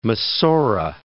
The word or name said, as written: Masorah